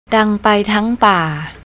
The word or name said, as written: dangpai